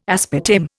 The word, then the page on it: aspartame: Indian